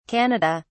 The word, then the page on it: canada: British English